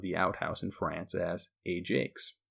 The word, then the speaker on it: Ajax: Now, you'll notice that that is pronounced Ajax, like J-A-K-E-S. But in France, either due to the accent or just the general French pronunciation of the word, Ajax is actually how they pronounce Ajax.